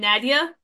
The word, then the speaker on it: Nadia: I go by my original pronunciation, Nadia. So that is the Arabic pronunciation.